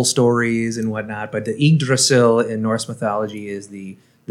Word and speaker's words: Yggdrasil: So number six is pronounced Yggdrasil. Yggdrasil. Yggdrasil.